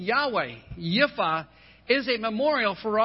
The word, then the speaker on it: Yahweh: It's pronounced Yahweh today. But in actually,